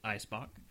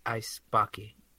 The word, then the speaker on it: Eisbock: There was some discussion pre-show as to exactly how to say it. And for English speakers, it is Eisbock. – Eisbocky. – No.